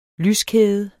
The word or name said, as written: lyskæde